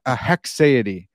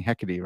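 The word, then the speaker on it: haecceity: So it's a hexaity. It's not that hard. It's close. Maybe I'm, but at least I'm not saying hecate, right? – Yeah.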